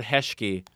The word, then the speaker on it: hezky: so I do know hezky, which hezky kluk might not be that handy as a tourist, but I have heard hezky. – You can, yeah, and you can say... And I fucked up the pronunciation. It's actually just hezky.